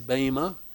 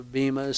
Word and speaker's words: bema: So the word behind seat there is bema. Or bema, some pronounce it.